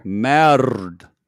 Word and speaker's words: merde: No, it's a U, merde. Your R. I'm saying you don't roll your R's.